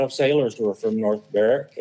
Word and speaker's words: Berwick: Oh, well, we showed up at North Berwick, which is spelt like Berwick. Only they say it as Berwick in Scotland.